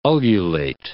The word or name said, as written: ululate